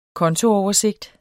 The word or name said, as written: kontooversigt